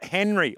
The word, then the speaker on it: Hnry: Tell us about Henry, I think is the pronunciation, H-N-R-Y.